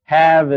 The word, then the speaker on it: have: Have is another good example. We have shave with exactly the same letter string in it. We even have behave. But have holds out for having a different pronunciation.